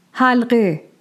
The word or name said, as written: Halgheh